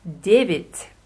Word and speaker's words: девять: Genau, auf Russisch David. – David. – David. – David. – Es wird mit Ja am Ende geschrieben, Devet. ausgesprochen aber David.